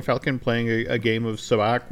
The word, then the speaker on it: Sabacc: Am I pronouncing it right? Sabacc? – Sabacc.